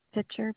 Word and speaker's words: Pitcher: Pitcher, P-I-T-C-H-E-R.